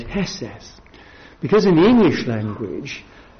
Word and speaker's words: hesed: Now, if we start in the Old Testament again, the Old Testament Hebrew for mercy is the word hesed, that's how it's pronounced.